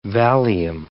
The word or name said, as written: Valium